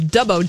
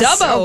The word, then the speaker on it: Dubbo: We're in the town of Dubbo. Did I say that right? – Yes. – Dubbo.